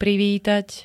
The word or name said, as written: privítať